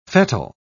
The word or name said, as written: fettle